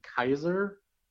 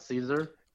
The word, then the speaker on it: Caesar: Apparently, you pronounce his name Kaiser, not Caesar. – Really? That's interesting. That's sounds German. But yeah, but the C in Latin, you pronounce it like a hard K sound, like K, so it's Kaiser.